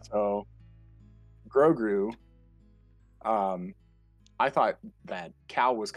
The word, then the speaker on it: Grogu: That's Grogu.